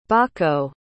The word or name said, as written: baako